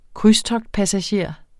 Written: krydstogtpassager

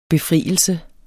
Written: befrielse

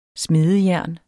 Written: smedejern

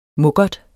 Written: mukkert

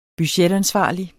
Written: budgetansvarlig

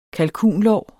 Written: kalkun

